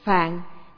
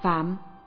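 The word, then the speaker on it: phạm (梵): Phạn, chữ Hán là phạn, nhưng thường đọc là phạm.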